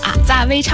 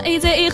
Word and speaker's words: 阿扎威: 这个组合名字叫做阿扎威唱团。 – 对，其实它的英文是 AZA，对不对？所以很多人在刚开始的时候会不会念成 AZA 合唱团。重申重要的事情，阿扎威唱团。 – 对，重申了一下，我们是阿扎，不是 AZA 啊，对不对？